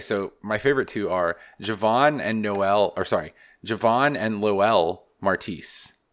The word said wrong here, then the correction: Narcisse: Or I said it wrong again. Damn it. Okay, so Javon Narcisse is a defensive back freshman.